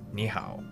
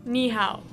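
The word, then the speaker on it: ni hao (你好): The most popular expression is ni hao. – Ni hao.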